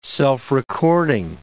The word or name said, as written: self-recording